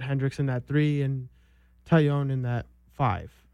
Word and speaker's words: Taillon: How do you say it? – Taillon.